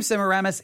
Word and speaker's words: Semiramis: It is spelled S-E-M-I-R-A-M-I-S, Semiramis.